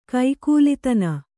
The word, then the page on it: ಕೈಕೂಲಿತನ: Kannada